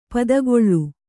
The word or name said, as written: ಪಿತ್ತಕಲ್ಲು